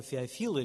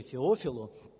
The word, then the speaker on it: Феофил: писал это достопочтенному человеку Феофилу или Феофилу, по-разному ударение ставит, то задача Луки была рассказать этому человеку, засвидетельствовать, каким образом Господь действует.